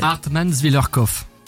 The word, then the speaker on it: Hartmannswillerkopf: Hartmannswillerkopf. – Voilà, c'est pas compliqué en fait. – Hartmannswillerkopf.